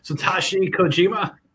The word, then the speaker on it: Satoshi Kojima: So Satoshi Kojima. – Satoshi. It's Satoshi. How you think it's Kojima? Yeah, you were close.